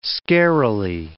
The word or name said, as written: scarily